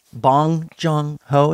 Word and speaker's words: Bong Joon-ho: Bong Joon-ho, is that how you say it? – Correct, yeah.